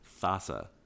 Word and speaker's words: Thassa: Thassia is cool. Like, she gets to exile, and I love exiling stuff. – Dave, you keep saying it wrong. It's Thassa.